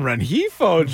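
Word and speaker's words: Rengifo: Then he walks Renjifo with the bases loaded. – Ooh, good pronunciation on Renjifo.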